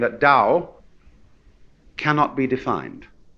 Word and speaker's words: Tao: T-A-O, you would think it was pronounced Tao or Tao, but we've Romanized Chinese in such a way that only those in the know can decide how it's pronounced. We should have spelled it D-O-W, you see, but it's T-A-O.